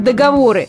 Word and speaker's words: договоры: Впрочем, не буду томить, и правильный тут вариант – договоры. А договора никуда не годятся.